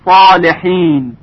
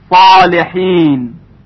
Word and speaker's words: صالحین: خوب دقت کنید در کلمه صالحین که با صاد نوشته میشه به خاطر استعلاء و اسباق حرف صاد صدای اون خیلی درشت شنیده میشه. صالحین، صالحین. اما در کلمه موسا توجه کنید حرف سین به خاطر داشتن صفات استفال و انفتاح با صدای ظریف‌تری تلفظ میشه. س، موسا، موسا. حالا دو مرتبه مقایسه میکنیم. ص، صالحین، صالحین. س، موسا، ص و س. پس صدای حرف صاد درشتر از صدای حرف سین هست.